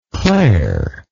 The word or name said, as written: clair